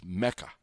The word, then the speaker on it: MEChA: That's Mecca. MECHA. By the way, it's spelled capital M, capital E, capital C, small h, capital A. Mecca, because you probably never heard of it, is kind of a student branch of La Raza, which I have talked about.